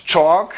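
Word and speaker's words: Chalke: Steve Schalke, or how do you Chalk? – Yeah, he is very how do you pronounce him? Chalk? Steve Chalk.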